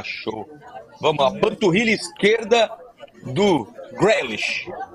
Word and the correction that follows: Grealish: É isso? Falei certo? É, o é Grealish.